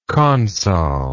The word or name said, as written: consol